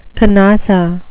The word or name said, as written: canossa